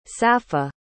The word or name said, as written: safa